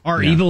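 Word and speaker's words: REvil: You may be aware of the ransomware group Reval. Is that the way you pronounce it? It's capital R, capital E, V-I-L. How do you pronounce it? – Or is it R-Evil? – R-Evil, yeah. Is that the way they say it?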